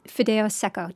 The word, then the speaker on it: fideo seco: Okay, tell us about fideo seco. Did I say that right? – You did say that right.